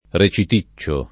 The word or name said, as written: reciticcio